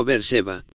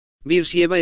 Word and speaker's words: Beersheba: Luego solo queda una ciudad más y creo que la pusimos en un círculo y esa es Beersheba, suena como Beersheba. Beersheba está aquí abajo. Dice cerveza. ¿Alguien tiene? ¿Alguna vez oíste decirlo como Beersheba? Beersheba, eso era cuando yo era más joven, así es como fue pronunciado.